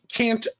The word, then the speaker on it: Kanto: So Kanto is literally Kanto. It's got like a long O symbol over it.